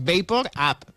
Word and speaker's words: VapoRub: No, s'escriu igual, el problema és com es pronuncia, però clar, és Vapor Up, perquè és el vapor que vagi cap a dalt.